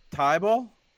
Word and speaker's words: Thybulle: Gene, we traded for our guy, Maurice Thybulle, yeah, that's how I've heard it pronounced.